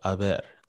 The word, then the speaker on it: haber: Yeah, so I is the present tense form of haber, there is, with a silent H.